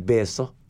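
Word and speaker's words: beeso: Og så uttaler de ikke P, de sier B, så de sier beso.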